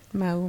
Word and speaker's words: mahu: Manu. Oui, Mao, Manu. Ça s'écrit euh, M-A-H-U pour les gens qui sont curieux puis qui veulent aller faire des recherches.